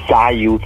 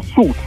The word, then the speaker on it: Suits: Sayuz, ma no! Si pronuncia Suits, come se non ci fosse la I. – Suits si pronuncia su. – Sì. – Lui dice SYUZ. Sayuz. – No, forse dice Suits, lui vorrebbe dire. Si dice Suits. Senza la I, senza niente. S-U-T.